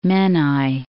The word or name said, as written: Menai Strait